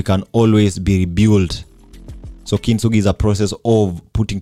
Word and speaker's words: kintsugi: So, of course, our keyword goes, or rather is pronounced as kintsugi.